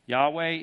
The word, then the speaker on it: Yahweh: Yahweh is the pronunciation I use, and most people today use, for the four Hebrew consonants.